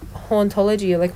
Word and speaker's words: hauntology: And then something interesting as well, the word hauntology, when Derrida started it, it was the word in the word in French sounds like the word ontology, which is the study of being.